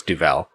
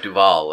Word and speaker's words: Duvel: I think it's Duvel. – What about Duval? Like Robert It's not Duval. Duval. There's no A in it. It's an E.